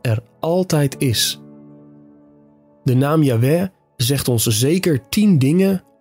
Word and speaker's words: Yahweh: In het Hebreeuws staat er dan een woord dat waarschijnlijk uitgesproken werd als Yahweh.